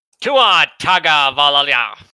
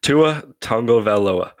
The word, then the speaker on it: Tua Tagovailoa: Tua Taga Valalia. Was I close? – It's Tua Tagovailoa.